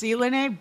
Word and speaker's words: Zlydnev: It's pronounced Zelene, but it's spelled Z-L-Y-D-N-E-V.